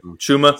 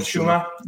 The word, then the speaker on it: Chuma: And he actually worked really well with Adupe, or however you say his name, Chimo Adupe. Is that Chuma. – So, I think it's called Chuma.